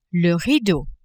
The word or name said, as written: rideau